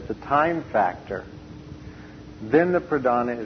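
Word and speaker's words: pradhāna: P-R-A-D-H-A-N-A with a long A, pradhana, which means the chief.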